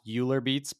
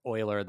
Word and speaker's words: Euler: It looks like it should be pronounced Euler Beats, but according to Wikipedia, it's like Euler the mathematician, and that's like how you say it.